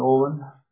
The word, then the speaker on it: Olin: Freddie Olin, O-L-I-N, with Gunnier Limited.